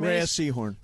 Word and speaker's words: Rhea Seehorn: I mean, the performances they're getting out of, is it pronounced Rhea Seehorn? – Rhea Seehorn.